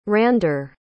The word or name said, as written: Rander